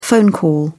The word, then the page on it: phone call: British English